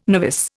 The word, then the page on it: novice: Indian